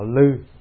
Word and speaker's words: Lư: Thưa Ngài Huệ Năng, Ngài vốn là họ Lư. Lư, có khi người ta đọc là Lô nữa. Lô Hành Giả hay Lư Hành Giả, hai từ này nó giống.